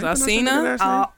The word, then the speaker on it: Alsina: I saw a picture circulating yesterday with August Azala or Azila. – August Alsina.